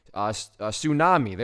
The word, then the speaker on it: tsunami: Tsunami." There we go. That T always throws me off. The T is silent.